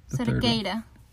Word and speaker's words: Cerqueira: How do you say that? Cerqueira? – Cerqueira.